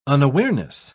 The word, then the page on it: unawareness: British English